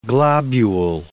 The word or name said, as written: globule